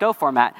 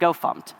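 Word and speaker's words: gofmt: It's called GoFormat, or depending on how you pronounce it, GoFumpt.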